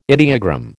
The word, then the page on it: ideogram: American English